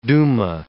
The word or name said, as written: duma